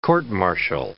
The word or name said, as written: court-martial